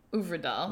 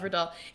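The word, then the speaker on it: Øvredal: It was directed by Andre Ovredal. – Ovredal. – Remember, I messed it up. Ovredal. It's Norwegian, so we did have to look that up.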